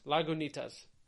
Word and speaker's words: Lagunitas: Wait, oh, it says say Lagunitas. – Lagunitas.